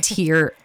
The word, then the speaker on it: Tiera: I spell it T-I-E-R-A, like it might say in the podcast somewhere. So I say it Tira, like it rhymes with Kira. It's like a tear right.